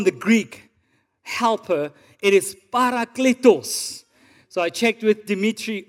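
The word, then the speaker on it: parakletos (παράκλητος): so I checked with him first. He said it's parakletos.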